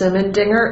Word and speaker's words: Simendinger: Nicholas is without an H, and Ritsinas is R-E-T-S-I-N-A-S. – And Alexis. – I'm Alexis Simendinger, and that's S-I-M-E-N-D-I-N-G-E-R, and I'm a member of the FCIC writing team.